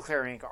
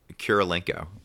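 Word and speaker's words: Kurylenko: Kirilenko.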